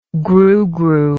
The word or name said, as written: grugru